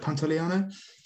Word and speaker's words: Pantoliano: Is it Pantoliano? – Yeah, we'll go with that.